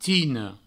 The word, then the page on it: tina: tino